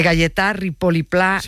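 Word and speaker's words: Gaietà Ripoll i Pla: a ver si lo digo bien, de Galletà Ripoll i Pla, un mestre lliure pensador que tenía la seua escola a l'Horta de Rusafa. – Perfecto. – ¿Lo he dicho bien? – Sí, y se ha entendido perfectamente.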